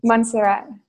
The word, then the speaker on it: Montserrat: In Montserrat, yeah. – Montserrat. Why is that place special and what are you doing there? Or what do you do there? – So Montserrat is a very special location for the ascension.